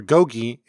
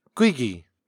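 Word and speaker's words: gogi (고기): The word meat, or gogi, is often pronounced gwigi.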